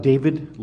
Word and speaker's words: David: Yes, my name is David Leis, and my name is spelled D-A-V-I-D, and my last name is L-E-I-S.